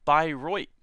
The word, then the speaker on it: Bayreuth: but yes, dozens, pronounced Bayreuth.